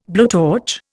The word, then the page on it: blowtorch: Indian